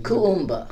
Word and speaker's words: Kuumba: Because I'm Kuumba. – Kuumba. – Yeah. So it's KU UMBA.